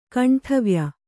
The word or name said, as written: ಕಂಠವ್ಯ